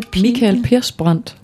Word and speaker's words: Persbrandt: So, und das Spannende ist, dass die Songs im Film von dem Schauspieler, dem schwedischen Schauspieler, say it again, it's Mikael Persbrandt. – Thank you so much. I couldn't have pronounced it any better myself.